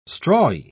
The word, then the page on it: strawy: British English